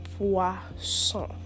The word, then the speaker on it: poisson: The second food is le poisson.